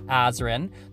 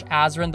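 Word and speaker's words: Azren: It is at Polyglot Azarin. That is spelled P-O-L-Y-G-L-O-T. A-Z or Z, depending on your country, R-E-N. You can also simply search Azarin the Language Nerd on whatever your favorite social network is.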